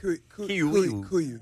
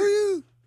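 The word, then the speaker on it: KUIU: Kuyu. – How do you say that? – Y'all, companies make it. – Kuyu.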